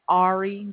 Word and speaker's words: Ari: Ari. That is spelled A-R-I.